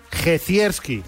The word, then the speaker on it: Jezierski: Esta tarde en Marca Gaming tenemos como invitado al actor Adam Gecierski, que además, quiero matizar, se pronuncia así, ¿no, Adam? Jezierski.